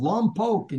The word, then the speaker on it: Lompoc: And Paul is in the thriving city there of Lompoc, and you got to say it like Polk, like the Cowboys.